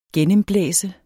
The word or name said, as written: gennemblæse